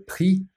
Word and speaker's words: prix: En français, le prix, and the X is silent.